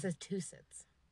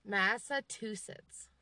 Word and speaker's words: Massachusetts: Massachusetts. – Isn't that what I said? – You were close. – Massachusetts? – No. It's not two shits. – No. – Massachusetts.